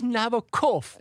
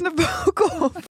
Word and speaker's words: Nabokov: Het is Nabokov. – Dit is Nabokov.